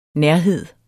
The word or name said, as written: nærhed